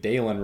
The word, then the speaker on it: Dylan: So if you're looking for silver linings, they did find a new, certainly at worst, a new number two receiver, and they were pronouncing it Dalen right on the broadcast. It is spelled like your run of the mill Dylan, but if Dalen is what it is, Dalen is what it is.